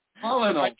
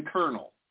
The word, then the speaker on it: colonel: There's more than one silent letter in colonel. First of all, colonel, it's not even right, because looking at the word colonel, there's no way that is pronounced colonel. I'm sorry. This is outrageous. – There's an R that doesn't even exist.